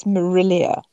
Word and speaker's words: Marillier: It's Marillier.